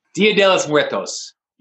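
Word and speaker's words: Día de los Muertos: Like, what, going over to how you capture a lot of like the Mexican Mexico's traditions with I'm gonna butcher the Del Muerte. – Dia de los Muertos.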